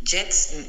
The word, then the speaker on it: Jetzt: Jetzt tienes que no pronunciar tanto la J. Jetzt, jetzt, no, es like una J muy suave, es una J.